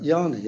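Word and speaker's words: Jarni: I'm going to say his name wrong. Robert Yarny? – Robert Yarny, exactly.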